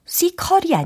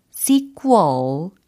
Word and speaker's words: sequel: 아, 특히 sequel 요 발음에 있어서 sequel이 아니에요. sequel 이렇게 꾸얼 요 사운드를 연습을 해주시면 좋을 것 같습니다.